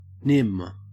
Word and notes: nim: Australian English

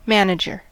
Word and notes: manager: American English

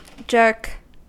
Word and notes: jerk: American English

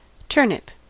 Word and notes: turnip: American English

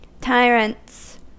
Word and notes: tyrants: American English

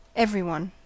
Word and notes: everyone: American English

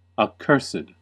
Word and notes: accursed: American English

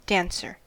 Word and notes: dancer: American English

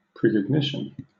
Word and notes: precognition: British English

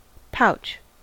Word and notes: pouch: American English

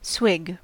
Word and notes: swig: American English

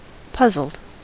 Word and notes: puzzled: American English

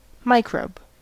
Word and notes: microbe: American English

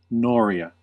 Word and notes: noria: American English

